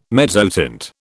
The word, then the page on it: mezzotint: British English